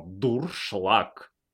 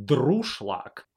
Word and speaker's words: дуршлаг: Это немецкое слово, обозначающее пробой-пролом, и произносится оно «дуршлаг». Если большинство заговорит «друшлаг», мы не должны будем просто переписать правила.